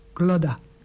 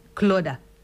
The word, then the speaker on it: Clodagh: Glodda Lawler. – Ja, ik denk het wel, hoor. – Het is C-L-O-D-A-G-H. Glodda. – Glodda. – Glodda.